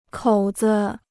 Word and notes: 口子: kǒu zi

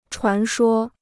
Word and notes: 传说: chuán shuō

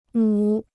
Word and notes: 母: mǔ

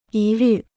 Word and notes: 疑虑: yí lǜ

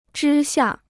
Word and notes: 之下: zhī xià

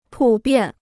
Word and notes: 普遍: pǔ biàn

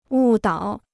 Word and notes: 误导: wù dǎo

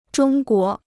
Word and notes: 中国: zhōng guó

